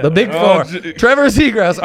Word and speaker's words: Zegras: Are you purposefully saying his name wrong? – How am I supposed to pronounce Trevor Zegras's name? – Zegras. – Are you sure about that? – It's not. There's no ass in his name.